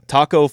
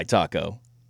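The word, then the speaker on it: Tacko: Taco Fall? – Taco Fall.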